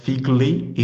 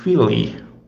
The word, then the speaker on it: figli: For example, uh, in Italian, uh, when you talk about children, your children, you know, um, you talk about figli would be written like F uh, I G L I, but the pronunciation is not figli, is filly.